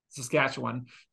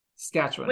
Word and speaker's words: Saskatchewan: we're going to have a little discussion on how to correctly pronounce Saskatchewan. You need to drop a lot of syllables. – Okay. – Corey's hitting every syllable on the way through. I know Corey likes to hit the buttons. That's what I hear. But we're an alliterator. – We're going to drop the A and the S.